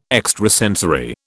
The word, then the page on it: extrasensory: British English